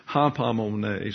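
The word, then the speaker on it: Hapamone (ὑπομονή): But nevertheless, the reason I wanted to go back and note some things is because we stopped at Hupomone. It's actually Hapamone, so you pronounce it as you wish.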